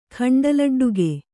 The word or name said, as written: ಖಂಡಲಡ್ಡುಗೆ